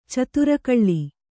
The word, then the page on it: ಚಿತ್ರಕಲೆ: citra kale